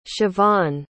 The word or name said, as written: siobahn